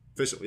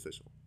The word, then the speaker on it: Fischl: Fischl, yeah. It was Fischl. – Fischl's cute, but... No, oh, sorry, never mind. – No. I'm done. I'm basically done. – Do you think Fischl's cute, but like her outfit's kind of...